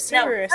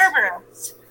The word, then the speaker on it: Cerberus: I thought it was Cerberus. No, Cerberus. – There's an R in there.